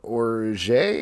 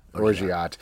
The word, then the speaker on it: orgeat: It's vodka, brandy, orange liqueur, orange juice, almond orge. How do you pronounce that? – Orgeat.